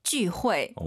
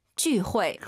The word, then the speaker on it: 聚会: 쥐가 아니고 주라고 많이 읽어요. – 주? – 제이유를 우리 방식으로 하면은. 주는 ZU예요, 여러분. 제이유는 쥐입니다. 그래서 다시 한번 들어볼게요. – 聚会.